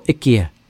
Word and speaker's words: IKEA: I don't think there'll be any problem with the IKEA Corporation, or IKEA, I believe is the Swedish pronunciation.